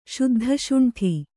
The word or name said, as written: ಶುದ್ಧಶುಂಠಿ